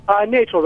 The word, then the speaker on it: Nachel: Nachel, rhymes with Rachel.